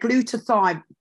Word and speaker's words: glutathione: Glutathione, okay.